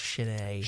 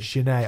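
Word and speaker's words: Sin-é: It's pronounced Cine. – Cine. Oh, is it? Cine. Yeah. There's a silent H.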